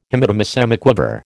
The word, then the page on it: Hemidemisemiquaver: American English